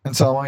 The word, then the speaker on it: Anselmo: And it was Alice in Chains with Phil Anselmo. Yeah. However you say his name. – Anselmo, yeah.